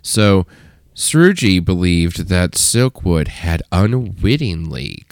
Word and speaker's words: Srouji: Jackie Srugi. Yes. I'm already a fan. – Her name is spelled J-A-C-Q-U-E. – Sure. – And sure. – Srugi is spelled S-R-O-U-J-I. – The J threw me.